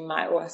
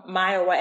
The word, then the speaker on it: Mayowa: I guess it's Myoa, like Iowa from um, my original name, Mayowa.